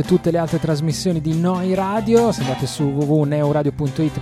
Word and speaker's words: Neu: Si scrive Neu, si pronuncia Noi.